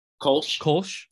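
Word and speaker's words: Kölsch: Kolsch. So it's like K-O-L-S-C-H.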